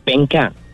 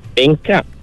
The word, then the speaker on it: pengkar: Kalau ejaan dia tu P-E-N-G-K-A-R lah, pengkar. Tapi sebutan bunyi huruf R dalam dialek Kedah ni, dia bertukar bunyi dia. Dia berganti dengan bunyi Q kalau dia di belakang lah.